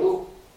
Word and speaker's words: eau: C'est une seule voyelle qui est eau. Alors ça s'écrit avec trois lettres qui n'ont rien à voir. Un E, un A et un U.